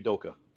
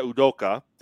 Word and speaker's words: Udoka: But Udoka, I think I pronounced his name right. Correct me if I'm wrong. – You mean Udoka. – Yeah, Udoka. Thank you.